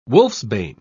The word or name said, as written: wolfsbane